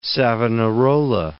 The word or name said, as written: Savonarola